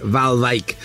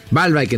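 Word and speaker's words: Waalwijk: Exactamente, RKC Valvik. – Valvik. – Bueno, acá, así lo pronuncian acá. – Yo quiero aprender a pronunciar bien. – Sí, Valvik entonces, Valvik.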